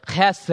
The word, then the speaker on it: hesed: It's the Hebrew word hesed, or if you want to say it right, you say it from the back of your throat, hesed, right?